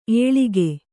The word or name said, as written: ಏಳಕ್ಕಿ